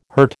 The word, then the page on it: hurt: American English